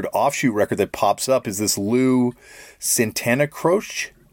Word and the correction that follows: Santa Croce: That's probably a terrible pronunciation. – Lou Santa Croce. – Croce. Sorry about that.